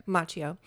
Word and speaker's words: Macchio: The original actors, Ralph Macchio. You pronounce the k. – Yeah. – Macchio.